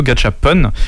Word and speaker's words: gachapon: À un moment, tu as évoqué alors tu as appelé ça les gachapons. Il me semble que ça se prononce plutôt gachapon.